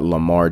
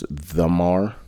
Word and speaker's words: Lamar: But he thought that Lamar Jackson's name was Themar.